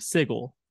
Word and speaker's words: Sigil: you, and one thing I think, like, when it comes to the city of Sigil, mm-hmm. which is spelled S-I-G-I-L, which is an actual word in the English language, which is pronounced sigil.